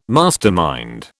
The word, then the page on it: mastermind: British English